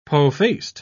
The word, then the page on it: po-faced: British English